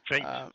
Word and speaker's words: feint: I don't know how to pronounce this word. It's FEINT. – Faint, Faint. – like if you're fainting.